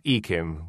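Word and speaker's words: Yquem: What is it? Yakim? – Yeah, it's pronounced like Ekim.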